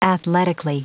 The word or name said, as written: athletically